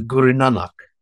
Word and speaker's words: Guru Nanak: And I wondered if we could tease a little bit about, I'm gonna pronounce it wrong, I'm sure, with the Guru Nanak.